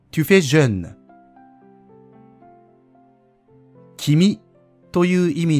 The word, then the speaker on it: jeune: jeune という発音が少し難しいですが、口をがっつり縦に開いて、おとえの中間のような、えという音を出します。